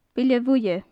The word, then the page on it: bile-bhuidhe: gaelic